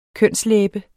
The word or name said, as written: kønslæbe